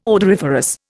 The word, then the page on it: odoriferous: Indian